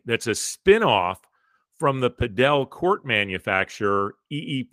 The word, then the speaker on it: Padel: It's P-A-D-E-L. It kind of looks like it could be pronounced paddle, and I thought it was until I looked into it, and it's the Pro Padel League, and they have their first team invested in L.A.